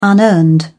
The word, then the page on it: unearned: British English